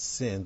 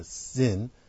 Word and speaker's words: Zin: And all the congregation, Exodus 17, all the congregation of the children of Israel journey from the wilderness of Zin after, and it is pronounced like that, not sin, but Zin,